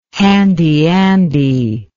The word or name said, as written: handy andy